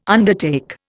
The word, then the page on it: undertake: Indian